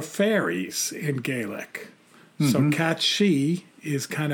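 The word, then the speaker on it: Cù Sìth: So it's C-U-S-I-T-H, but they say it's pronounced Cat-She.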